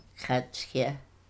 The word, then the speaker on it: khatsya: How do you say that? – Khatsya. – Khatsya. – Yeah, khatsya. Yeah.